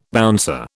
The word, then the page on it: bouncer: British English